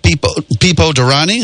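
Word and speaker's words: Pipo Derani: Then the drivers, Felipe Nasr, Eric Curran, and Pipo Durrani. Is that the way you pronounce that? That's correct.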